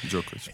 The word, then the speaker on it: Djokovic: parla di Novak Djokovic. – Sì. – L'ho detto bene? – Sì, sì, Djokovic.